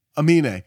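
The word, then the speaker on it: Aminé: God, I always spell it. I pronounce his name wrong. Amine. He's from Portland.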